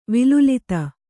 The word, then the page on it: ವಿಲುಲಿತ: vilulita